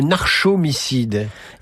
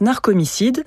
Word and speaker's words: narcomicide: Alors, il y a un mot qui s'appelle le narcomicide ou le narchomicide? – Eh bien, je pense qu'on prononce narcomicide. Bon.